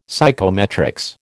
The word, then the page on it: psychometrics: American English